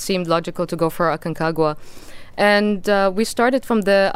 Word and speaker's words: Aconcagua: Now, tell us about your experience climbing this Mount Aconcagua. Is that how you say it? – Yes, yeah. That's correct, yeah.